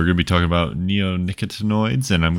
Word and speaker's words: neonicotinoids: All right, now neonicotinoids.